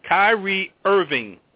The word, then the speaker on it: Kyrie Irving: Would you trade Tyree Evans? And if you can't pronounce his name, you shouldn't go there. Kyrie Irving. – I'm sorry, Irving. I spelled it right. I just said it wrong.